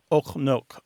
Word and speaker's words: ook nog: The pronunciation is something like ook nog, something like that.